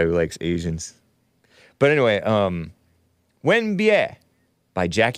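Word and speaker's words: Wenbie (吻别): This track is called Wen Bie. Let me just double check the pronunciation, because in the pinyin it matters.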